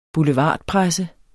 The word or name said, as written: BOULEVARDPRESSEN